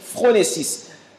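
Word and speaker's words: phronesis (φρόνησις): Et cette sagesse-là se prononce phronesis.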